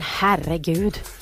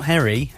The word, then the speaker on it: herregud: No, not Harry. So Harry. You have to roll your R's. – Yeah. – Herre. – I can't. – Herregud.